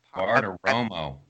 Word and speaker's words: Bartiromo: Bartomo. I always say her name wrong. I Bartiromo. – Bar Bartiromo.